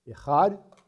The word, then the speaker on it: echad: The first one is the word echad, E-C-H-A-D, pronounced echad.